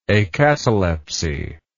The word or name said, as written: acatalepsy